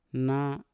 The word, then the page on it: na: Mpur